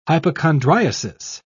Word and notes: hypochondriasis: British English

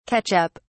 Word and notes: catch up: British English

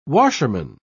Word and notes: washerman: British English

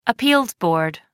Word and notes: appeals board: American English

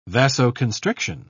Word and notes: vasoconstriction: British English

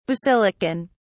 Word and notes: basilican: British English

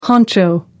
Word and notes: honcho: American English

